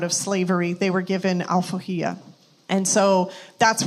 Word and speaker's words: Alforria: So the name of the coffee, I'm going to help you pronounce it. It's Alforria. Alforria in Portuguese, or in Brazil specifically, I guess the closest word that you would have in English is emancipation.